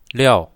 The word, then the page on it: liao4: mandarin